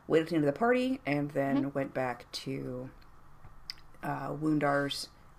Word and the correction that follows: Rundar: Rundar. It's an R. Rundar.